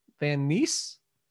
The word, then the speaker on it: Van Nuys: That's how you pronounce that, right? N-U-Y-S, Van Nuys, Van Nuys, Van Nuys sounds right.